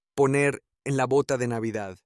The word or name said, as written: poner en la bota de Navidad